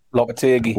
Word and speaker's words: Lopetegui: Lopetegui.